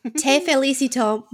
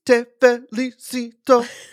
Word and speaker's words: Te Felicito: Te Felicito by Shakira. – I'm sorry, you said that wrong. It's Te Felicito by Shakira and Raúl Alejandro.